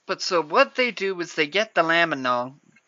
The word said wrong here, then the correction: Lambanog: Lambanog? I think it's Nog, like eggnog? Lambanog? – Yeah.